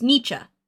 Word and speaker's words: Nietzsche: So it's Nietzsche.